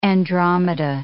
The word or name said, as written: Andromeda